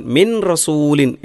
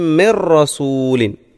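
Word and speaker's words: മിർ റസൂലിൻ (مِنْ رَسُولٍ): യഅതീഹിം മിർ റസൂലിൻ, മിൻ റസൂലിൻ എന്നല്ല, യഅതീഹിം മിർ റസൂലിൻ, മിർ റസൂലിൻ. ആ സുക്കൂനുള്ള നൂന് റായിൽ അലിയുകയും ലയിപ്പിക്കുകയും എന്നാൽ മണിക്കുകയും ചെയ്യാതെ ഇത് ആ മുമ്പിൽ ആവുന്ന ഇത് ആ മുമ്പിൽ ആവുന്ന അങ്ങനെയാണ് തോന്നേണ്ടത്. യഅതീഹിം മിർ റസൂലിൻ ഇല്ലാ കാനു ബിഹി യസ്തഹ്സിഊൻ എന്ന് പാരായണം ചെയ്യാൻ ശ്രദ്ധിക്കുക.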